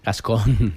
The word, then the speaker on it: Gascón: Tú imagínate Carla García Gastón. – Gascón. – ¿Cómo se dice? – Gastón era el de La Bella y la Bestia. Gascón. – Gascón.